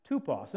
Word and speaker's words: tupos: I know it looks like typos up there, but it's actually pronounced tupos.